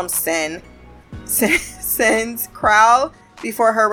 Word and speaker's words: kraal: So it is pronounced kraal, and it is a traditional African village of huts typically closed by a fence.